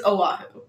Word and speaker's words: Oahu: That's not how you pronounce it. It's Oahu.